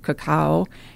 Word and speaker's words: cacao: Again, marketing flipped the A and the O, and now it's pronounced cacao, and people are going crazy about it because they think it's the superfood.